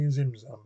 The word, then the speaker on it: Tzimtzum: In this, the Ein Sof contracts through a process called Zimzum, pronounced phonetically Zimzum but spelt T-Z-I-M, T-Z-U-M, at the start of creation to allow room for divine expansion.